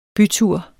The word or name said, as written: bytur